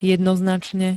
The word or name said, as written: jednoznačne